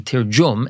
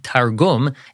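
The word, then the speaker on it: Tirjum (תרגום): The reason why we say Tirjum and not Targum is because in the Temani way of pronouncing Hebrew, when a gimel has a dagesh, a dot in it, it's pronounced J instead of G.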